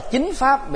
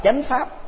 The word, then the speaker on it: Chánh Pháp: Miền Bắc đọc là Chính Pháp, miền Nam đọc là Chánh Pháp.